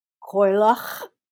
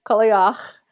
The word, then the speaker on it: Cailleach: And her name I have heard pronounced as Coilach, Kaliach, or just as one writer described it, the sound of a throat clearing. – That's, yeah. – So I'm going to call her Kaliach. It's spelled C-A-I-L-L-E-A-C-H. Kaliach.